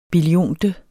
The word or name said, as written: billionte